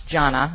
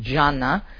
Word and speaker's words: Gianna: and it's pronounced Gianna. I learned that when I was over there. It's not Gianna, it's Gianna.